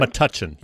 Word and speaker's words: Metuchen: I'm going to say this wrong, Metuchen, New Jersey? – Metuchen. – Does that sound about right? – Metuchen, yes.